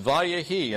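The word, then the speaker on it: vayehi (ויהי): It's pronounced vayehi, and it means, and it came to pass.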